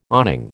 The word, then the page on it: awning: American English